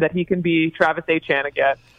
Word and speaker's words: Etienne: And there was a video from his time in college where he said, you know, it actually is Achan, but the other way is fine too.